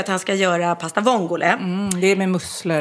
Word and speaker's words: vongole: Så att han uttalar vongale istället. Pasta vongale.